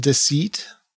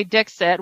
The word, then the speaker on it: Dixit: Someone said that if you say Dixit, which is how we've been saying it because we're from Virginia, if you say it with more of a French pronunciation, it sounds like deceit. Because it's D-I-X-I-T, so it'd be more like deceit if you did it like that,